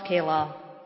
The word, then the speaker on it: Kalal: State Ex Rel Kalal, K-A-L-A-L, if I've mispronounced that. That's. – I think it's Kalal.